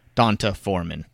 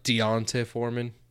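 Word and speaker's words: D'Onta Foreman: This guy I'm not excited about, Donta Foreman. I hate saying his name to begin with. It's very. – It's more like Deonta Foreman. – I don't even know how to say it, but it's annoying.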